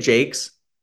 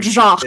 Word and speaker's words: Jacques: Is that how you say it, Jakes? – I know. I was so disappointed, but I did look it up, and it's not Jacques.